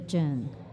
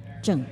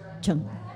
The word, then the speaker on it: Cheng: Well, the correct is actually Jen, but I know most of the Americans would pronounce it as Cheng. – Cheng. – So how do you spell that? – C H E N G, and it should be Cheng.